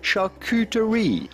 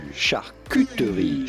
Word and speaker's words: charcuterie: and the French pronounce the U, U, charcuterie, charcuterie. Now in French, you say charcuterie, charcuterie, charcuterie. – I just call an adult lunchable. – But in English, charcuterie.